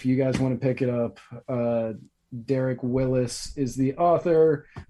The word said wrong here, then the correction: Wills: Or sorry, Wills. Derek Wills. – Oh, nice. – Not Willis. I've been reading his name wrong for years now.